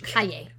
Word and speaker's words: calle: The L's are, I'm not pronounced like that in Spanish. Calle.